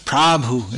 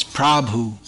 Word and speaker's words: Prabhu: Prabhu Nitya, Prabhu. And here in Croatia it's Prabhu, but in India it's Prabhu, not Prabhu. Just a point of pronunciation.